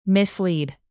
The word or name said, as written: mislead